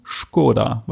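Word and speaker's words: Škoda: Das Auto Skoda nur in Skoda, weil so wird es tatsächlich ausgesprochen.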